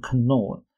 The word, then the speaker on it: Knorr: 出生在一九四六年的三月十四日，他的姓呢，Nor K N O R R，其实就是康宝浓汤的康宝。康宝啊，虽然现在是联合利华旗下的品牌，不过它在被并购之前呢，是一个德国的牌子。如果用德文发音的话，那个 K 是会发音的，就会变成 Knorr。